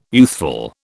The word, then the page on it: youthful: British English